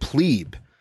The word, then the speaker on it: plebe: Which is one definition of the word plebe, and not the derogatory usage of the word plebe, or sometimes pronounced pleb, which dates back to Roman class divisions between patricians and plebeians.